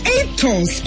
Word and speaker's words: Aetos: Spell it, mommy. – A-E-T-O-S. Etos. – Etos. Correct. Etos sunflower oil.